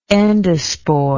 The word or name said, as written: endosporously